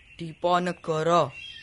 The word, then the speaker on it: Diponegoro: Nah, coba perhatikan pengucapan atau pelafalan nama Diponegoro. Jangan sampai kita salah melafalkannya, misalnya Diponegoro, gitu ya. Nah, jadi vokal O kadang-kadang dilafalkan penuh seperti kata kota. Kadang-kadang dilafalkan O, misalnya Diponegoro, Lombok, pohon, rontok, dan sebagainya.